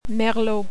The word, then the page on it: Merlot: French